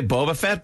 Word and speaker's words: Boba Fett: No, but the way you said it was brilliant because you sped up Boba, so he's so fast. I would say Boba Fett, but I like the way you did it.